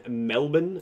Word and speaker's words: Melbourne: Said, y'all hear the crazy stuff going on in Melbourne? And I said it correctly because Dylan would have not said it correctly. – Yeah, I'm glad you did say it correctly.